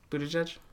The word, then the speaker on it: Buttigieg: Also, I had no idea that's how you say his name. – Buttigieg? – Yeah.